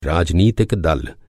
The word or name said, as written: दल